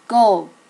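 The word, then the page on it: go: native Japanese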